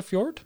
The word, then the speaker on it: fjord: Would you not call it a fjord? – I think it's pronounced fjord. – Don't they call it a fjord? – I think it's a silent J.